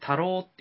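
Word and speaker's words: 田老: で、それはまあいろんな多方面に及ぶんですけど、まあ僕が、まあ、そのメンバーの一人として参加したのは、宮古市の中でも、えー、太郎っていう田んぼの田に老いると書いて、太郎と読む、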